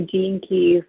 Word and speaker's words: Gene Keys: You know, you work with crystals and cards, but I've seen it says gene keys or gen keys. How do you say it? Is it gene keys? – Gene keys, yeah.